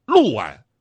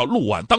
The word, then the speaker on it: 六安: 比方著名的这个安徽六安，对吧？这个事儿大家伙都知道，人家历史上就叫六安，当地人也叫六安，大大小小的人都叫六安。